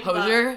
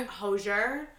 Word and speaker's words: Hozier: Hozier? – Hozier.